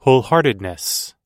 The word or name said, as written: wholeheartedness